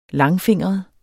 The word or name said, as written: langfingret